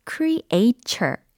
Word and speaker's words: creature: 저는 어렸을 때 이 단어 발음을 처음에 creature 이와 같이 배운 적이 있어요. 그래가지고 한참을 무의식적으로 그렇게 발음을 해왔거든요. 근데 사실 정확한 발음은 어때요? creature, creature 이거지. creator이 아니다. 라는 것을, 어, 짚어드리고 싶었어요. 어, creator이 아니라 creature, creature. 연습하고 계시죠? 어, 물론, 동사 create 라는 철자 기억나시나요? C-R-E-A-T-E.